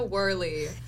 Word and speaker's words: Woehrle: Yes, it's Hannah Woehrle. – It's Woehrle, not Woerhle, not Woerhle. It's Woehrle.